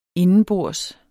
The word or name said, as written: indenbords